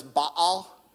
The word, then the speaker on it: Baal: He studied linguistics at UCLA, PhD, and he actually taught me that it's Baal. So sometimes I say it that way. If I say it that way, just forgive me as a weird nerd, all right? Please do. But that's the proper way to say it, apparently. We've been saying it wrong all along if you grew up in church.